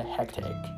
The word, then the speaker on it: hectic: よく聞くと句がちょっと入ってるので hectic hectic hectic っていう発音でした